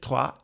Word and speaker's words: Troyes: Indien, Lommel in Belgien, Troyes, auch das habe ich äh, gegoogelt, wie man das ausspricht, Troyes in Frankreich, ähm, Palermo in Italien und der zwölfte und letzte Club äh, im Mai diesen Jahres eingekauft ist Bahia in Brasilien.